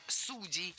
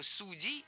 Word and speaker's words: судей: Раньше оба ударения были допустимы, но сейчас выбор сделан, и актуален только вариант «судей» с ударением на корне. Так что никаких поблажек, только судей, судьям, судьях.